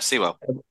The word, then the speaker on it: Sewell: Sewell? Sewell? S-E-W-E-L-L. – Yeah, Sewell.